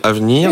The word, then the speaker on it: Avneer: Alors sur un autre registre évidemment, l'application Avenir, hmm. donc je rappelle, ça s'écrit A V N E E R.